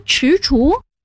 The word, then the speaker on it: 踟蹰: 那两个字读踟蹰，不是蜘蛛。 – 踟，左边是足字旁，右边是知道的知，也难怪你读作知，正确读音是踟。而蹰左边也是足字旁，右边是厨师的厨，读作厨。